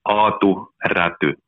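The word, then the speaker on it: Aatu Räty: So it's Atu Ratu. – Awesome. – How you pronounce it? It's difficult. – I love the way you roll the R's.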